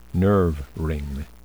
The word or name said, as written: nerve ring